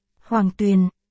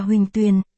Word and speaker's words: Hoàng Tuyền: Hoàng Tuyền, còn đọc là huỳnh tuyền, dịch là suối vàng.